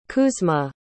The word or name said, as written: kuzma